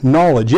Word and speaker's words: knowledge: The word knowledge, you ever wonder why knowledge in English is spelled with a K? Knowledge? It's brought over from the Greek, and the guttural kind of k sound at the beginning came from a g sound originally.